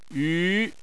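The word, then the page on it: yu: tone 2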